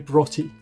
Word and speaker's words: Broughty: Broughty. Broughty. Just imagine B-R-O-T-T-Y.